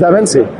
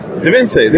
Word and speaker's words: Divensi: Is it pronounced Divensi or? – Yes, it's Divensi. – Divensi, there you go.